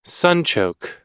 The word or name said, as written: sunchoke